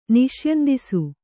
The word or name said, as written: ನಿಷ್ಯಂದಿಸು